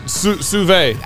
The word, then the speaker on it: Sauvé: Brian Sauvé.